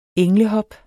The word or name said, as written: englehop